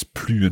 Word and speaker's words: plus: And note that S there on plus. We have to pronounce it because it's more time. It's not no more time, but more time.